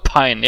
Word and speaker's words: Pyun: Pine. Pine. Pine. Pine? – Genau. Okay. – Albert Pine,